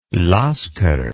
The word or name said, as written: Lasker